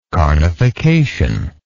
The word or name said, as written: carnification